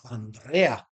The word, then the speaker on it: Andrea: We say, for example, my name is Andrea, right? But it's pronounced Andrea. Right, the R's rolled.